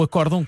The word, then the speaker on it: acórdão: Um deles é o acórdão que tem vogal fechada na sílaba cor.